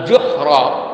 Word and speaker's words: juhr: juhra lauda kalu juhra ad juhr dibacanya dengan domah ju bukan dengan ja itu artinya as asarap ini lubang pada tanah.